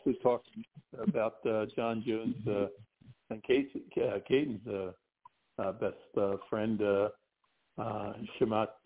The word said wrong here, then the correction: Hamzat Chimaev: Kayden, how you say it? – Oh, Hamzat Chimaev. Yeah.